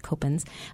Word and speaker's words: Coppens: My last name looks like Coppens, but it's pronounced Copens.